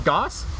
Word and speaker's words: gose: It's their black currant goss. I believe is how you say that. Goss. – Yeah. – G-O-S-E. – It's one O short of goose.